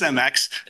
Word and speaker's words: CEMEX: But should we be considering this under Gissel or CEMEX? I don't know if I'm pronouncing that correctly. – I believe it's CEMEX.